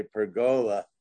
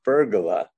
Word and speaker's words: pergola: And I heard you say pergola, and that apparently is the approved American pronunciation, which surprised me. The English and Italian pronunciation is pergola.